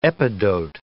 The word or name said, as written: epidote